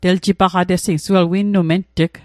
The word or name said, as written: ment'g